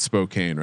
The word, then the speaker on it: Spokane: I feel like this is one of the words we always mispronounce. – Spokane. – Spokane. – I think it's Spokane, right, Colby? – Yeah, shout out to Wolfies in Spokane.